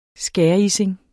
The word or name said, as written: skærising